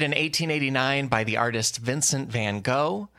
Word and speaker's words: Vincent van Gogh: I think the actual Dutch pronunciation is along the lines of Vincent van Gogh. And you don't say the G on the front. Gogh is the tricky last word there.